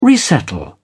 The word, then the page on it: resettle: British English